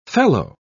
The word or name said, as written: fellow